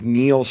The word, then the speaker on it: Neal: So my name is Neal Schaffer. I am the real Neal. So it's N-E-A-L.